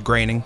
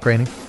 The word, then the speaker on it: Groening: Matt Groening turning 70 today. He's the creator of Futurama and The Simpsons. Did I pronounce that right? – I think it's Groening. – Groening.